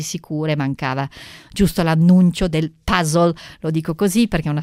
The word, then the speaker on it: puzzle: È vero, puzzle è una parola inglese, si pronuncia appunto così. Io a volte dico puzzle perché così mi sembra che in italiano forse ormai siamo abituati a dire così.